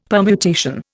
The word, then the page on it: permutation: Indian